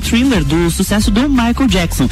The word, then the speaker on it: Thriller: O vocalista do Imagine Dragons, Dan Reynolds, revelou que sempre amou o vídeo de thriller. É, tá certo? Eu tô falando certo? – Thriller, é aham.